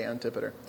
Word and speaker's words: Antipater: There's a weird story I alluded to last week where 3,000 Israelite soldiers, led by the general Antipater, Different people pronounce that differently. I will say Antipater.